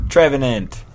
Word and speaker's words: Trevenant: So Trevenant.